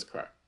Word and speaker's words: craic: Maybe I just said crack or craic. It's a craic. It's crack. I'm pretty sure it's crack.